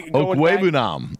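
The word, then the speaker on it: Okwuegbunam: Okwuegbuhem. Hopefully I didn't dishonor his heritage, but they're in Denver. And then Okwuegbuhem. Okwuegbuhem. – Okwuegbuhem. Yeah, I missed a syllable there.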